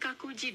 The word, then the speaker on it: Kinkakuji: Bagaimana jika Anda ingin pergi ke kuil King Kinkakuji di Kyoto? Pengucapannya adalah King King Kinkakuji.